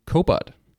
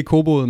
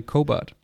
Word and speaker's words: Kobot: Og du sidder som CMO hos teknologivirksomheden Kobot. – Det er fuldstændig rigtigt. Flot udtalt, ja. – Ikke Kobot, men Kobot.